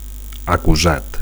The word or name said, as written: acusat